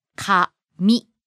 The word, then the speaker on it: kami: Different region have different pitch accent, but in Tokyo area, we say kami. – Rising. – そうです。 – Starts low and goes up on the second syllable.